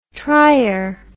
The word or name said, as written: trior